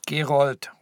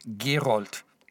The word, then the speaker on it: Gerold: Gerold. Robert Gerold. Den Nachnamen schreibt man G-E-R-O-L-D.